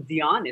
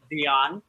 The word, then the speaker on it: Djon: So my name, Dion, is spelled D-J-O-N, no I, said like Celine, not like the mustard. It's fancy, I'm not. But one more time is Dion, D-J-O-N.